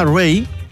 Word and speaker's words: Raye: ¿Qué pasa con esta artista llamada Ray, que se escribe R-A-Y-E, Raye?